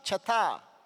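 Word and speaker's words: chatá (חטא): Para empezar, en el original hebreo, la transliteración de pecado se escucha chatá.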